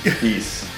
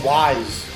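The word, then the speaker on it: Ys: Yeah, like it rhymes with fleece. East. – Because I remember being a kid and seeing copies and I'd be like, why is it called Wise? – Wise.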